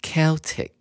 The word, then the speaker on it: Celtic: 首先，我们来看第一句：Harvest festivals date back to the Celtic culture of old times。丰收节可以追溯到古时候的塞尔提克文化。 Celtic，C-E-L-T-I-C 这个字呢，也有人把它念成 Celtic。